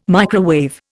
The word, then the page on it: microwave: Indian